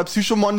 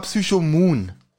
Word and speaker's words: Psychomoon: Also erstmal hat das falsch ausgesprochen, weil Psychomon, das klingt wie ein Pokémon. Ja, ja, das stimmt. Das heißt aber Psychomoon.